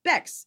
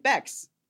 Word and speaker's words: Bex: I used to like say, because like Bex is kind of an uncommon name, so I would be like, Bex, it rhymes with T-Rex. But now I'm not afraid to say Bex rhymes with sex.